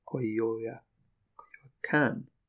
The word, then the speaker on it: Coyoacán: Coyoacán. The accent is on the last A.